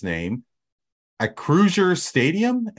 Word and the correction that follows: Acrisure: At Acrisure Stadium. Does anybody know what that industry is? – Acrisure.